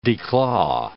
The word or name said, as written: declaw